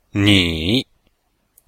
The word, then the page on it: ni: ni3